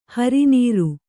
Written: ಹರಿನೀರು

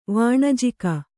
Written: ವಣಿಜಕ

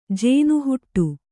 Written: ಜೇನುಹುಟ್ಟಿ

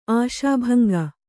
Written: ಆಶಾಭಂಗ

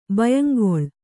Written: ಭಯಂಗೊಳ್

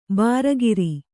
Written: ಭರಿಕರ

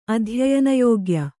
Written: ಅಧ್ಯಯನಯೋಗ್ಯ

